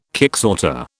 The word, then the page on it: kicksorter: British English